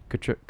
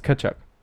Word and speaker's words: Tkachuk: Is that how you say it? – No T. – I see. I've heard broadcasters saying. – Yeah, like those guys don't know what they're talking about. – Kachuk. It's Kachuk. Kachuk.